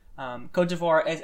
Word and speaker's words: Cote d'Ivoire: Don't you mean Cote d'Ivoire? – Oh, that's right. Good catch. Cote d'Ivoire is the preferred pronunciation for their country, and they've actually asked in official diplomatic forums that they not be referred to as the Ivory Coast with that English translation.